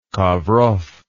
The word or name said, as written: Kovrov